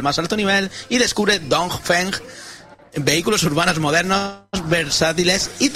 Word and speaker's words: Dongfeng: Dongfeng. – XPeng y Dongfeng. – Dongfeng. – Es que si pronuncias mucho la G, al final lo liamos.